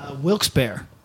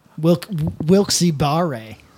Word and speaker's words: Wilkes-Barre: Wilkes-Barre. – Okay, good. – Wilkes-Barre.